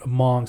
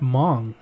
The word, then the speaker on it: Hmong: And before I moved to Fresno, I never heard of Hmong, so I remember, what is that Hmong? And it's like, are they from Mongolia? Like, where are they from? But it's like there's a silent H in it.